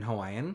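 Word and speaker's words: Hawaii (Hawaiʻi): And it's, very present in Hawaiian. – Mm-hmm. I mean, isn't the correct pronunciation Hawaii? – Yeah. It has the glottal stop right in it.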